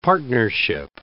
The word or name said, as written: partnership